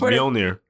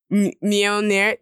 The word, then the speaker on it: Mjolnir: It's Mjolnir. – Mjolnir? Yeah, the J is silent.